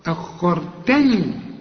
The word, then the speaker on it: أخرتني: لولا أفكر تاني، هنا بقى عاوز دقة في النطق، ما تقولش آه، ما تقولش أخرتا، أخرتني، رأى وفخم، وفخم برضو الراء والتاء ترقق معا، أخرتني، ومد أربع حركات إذا وصل، ولازم توصل أهي علشان بعد المد همزة أهي قدامك.